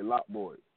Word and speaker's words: Lot Boy: Yeah, Lot Boy, like a whole lot, but also like a parking lot.